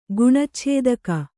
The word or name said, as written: ಗುಣಚ್ಛೇದಕ